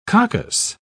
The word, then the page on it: coccus: British English